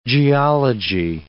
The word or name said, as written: geology